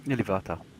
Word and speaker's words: Ilúvatar: Ilúvatar. – Ilúvatar. Damn it, I should know that. Was never for the seat of Ilúvatar by the chorus of the Ainur and the sons of men after the great end.